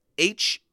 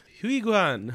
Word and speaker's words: Heughan: Sam Heughan. – Oh yeah, baby. Heughan.